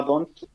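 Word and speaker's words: D'Hondt: que es el sistema tonto se, llama Don't, se escribe muy raro, es como una D apóstrofe H O N D T.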